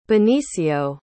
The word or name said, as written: benicio